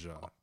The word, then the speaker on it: Ja: But they say Ja. – It's not Lil TJ. – I know, whatever. – It's Ja. J-A.